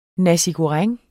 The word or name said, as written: nasi goreng